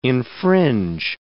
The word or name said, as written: infringe